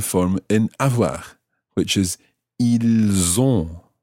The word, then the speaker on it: ils ont: So that's I-L-S. And the verb itself is O-N-T. So what you end up with is pronouncing the S of the il in that one because it's following, or it's followed by a vowel. Ils ont. – Ils ont. – And in that situation, the S of il sounds more like a Z. Z, as opposed to S. Z. Ils ont.